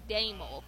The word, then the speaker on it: Damel: We called him Colin Dimmel every day, every time. And he told me, he said, it's actually Damel. – Damel. – It's not spelled like that. – I had the mole part right, but thank you yes, for letting me know.